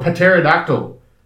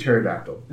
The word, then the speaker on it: pterodactyl: He goes, I'm a pterodactyl. What is a pterodactyl, not a pterodactyl? No, there's a P in the front. I said, the P is silent.